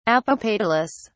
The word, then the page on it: apopetalous: British English